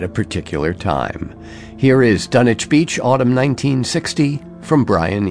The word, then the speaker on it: Dunwich: It looks like Dunwich Beach, but I'm pretty sure it's pronounced Dunwich.